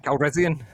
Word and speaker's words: Calrissian: How do you say his name? – Calrissian. Lando Calrissian.